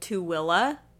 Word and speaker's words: Tooele: Or there's another city not too far from Salt Lake called Tooele that is spelled T-O-O-E-L-E. T-O-O-E-L-E. Tooele. Not Tooele.